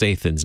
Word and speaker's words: Athens: Spelled the same way, it's pronounced Athens, not Athens. Anyway, okay.